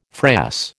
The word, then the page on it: frass: American English